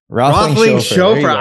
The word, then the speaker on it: Roethlingshoefer: Roethlingshoefer.